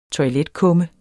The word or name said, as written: toiletkumme